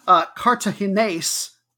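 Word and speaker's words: Cartaginés: They advanced to the final over third-seeded, Cartagines and do a really American, Cartagines, there we go, get my emphasis on the right syllable, 2-5 on aggregate, goodness.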